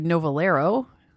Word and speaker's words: Novillero: went on to form Noviero, which is spelled N-O-V-I-L-L-E-R-O. You could say Novalero, if you want to pronounce it the English way.